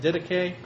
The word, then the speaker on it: Didache: That's how you pronounce that, Didache, Greek word for teaching.